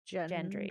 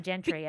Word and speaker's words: Gendry: Gendry. Gendry. I always say it wrong. – I was about to call him Gentry, and I knew that wasn't right.